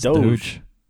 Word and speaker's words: Doge: What's the pronunciation? – Pretty sure it's Doge.